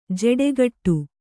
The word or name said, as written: ಜೋಡುಗೂಡು